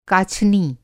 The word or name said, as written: काछनी